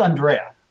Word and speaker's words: Andrea: Did I pronounce that right, by the way? – It's Andrea. – Andrea. – It kind of throws people off. My father was actually Italian.